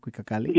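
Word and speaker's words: Cuicacalli: And we're here to talk about your fantastic new collection, Cuicacali, if I say that right. Am I saying that right? Cuicacali? – Yes.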